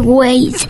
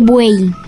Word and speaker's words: buey: No se dice güey, se dice buey al animal.